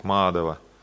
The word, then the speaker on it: Мадхава: И так как там ударение не как в русском языке, там обычно ударение в русском языке в стихах на первый или второй слог в конце, здесь третий слог «Мадхава».